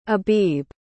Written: abib

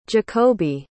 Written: jacoby

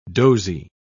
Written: dozy

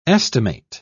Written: estimate

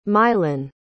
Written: Mylan

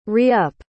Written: re-up

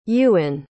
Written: Ewin

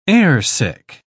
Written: airsick

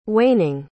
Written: waning

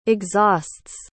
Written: exhausts

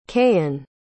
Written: kayin

